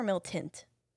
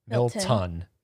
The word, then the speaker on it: Milton: Or is it with a T at the end or an N? Milton or Miltint? – Ton. Milton. – Milton. – Yeah. Okay. M-I-L-T-O-N.